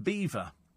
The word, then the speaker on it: Belvoir: Beaver Castle, and but it's it's spelt Belvoir, and it's um, it was changed over the years because people couldn't pronounce the French Belvoir, so they, eventually it became to Beaver.